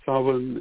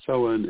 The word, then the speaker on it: Samhain: And, you know, it can be Samhain, it can be Samhain, it can be Samhain, it can be Samhain. And each of these is correct somewhere and others.